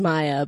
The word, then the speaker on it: Mia: My first name is the one I get the most mispronounced because I spell it M-I-A, but right. – It's pronounced Maya.